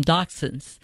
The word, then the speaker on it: dachshund: Well, I don't go into the German pronunciation. I just call them dachshunds.